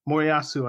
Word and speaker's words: Moriyasu: Your manager, Moriyasu, I think is the pronunciation.